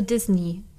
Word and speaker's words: Disney: Und, und da gab es ein Forum und da hat nämlich äh, jemand geschrieben, yes, Disney with the ending to rhyme with see me be. Also Disney.